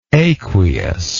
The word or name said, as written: aqueousness